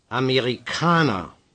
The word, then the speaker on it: Amerikaner: Amerikaner. – Stress on the A.